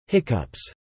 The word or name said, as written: hiccups